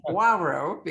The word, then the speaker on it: Poirot: Poirot, which is like Poirot, because the OI in French is pronounced wa.